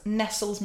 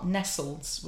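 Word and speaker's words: Nestlé: Do you remember when it was Nestles when I was a kid? Nestles. Nestles Milky Bar. Nestles was the ad. Yep. Then all of a sudden, it's Nestle.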